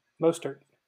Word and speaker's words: Mostert: How do you say his name? – Mostert. – Mustard. Okay.